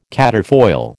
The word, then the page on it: quatrefoil: American English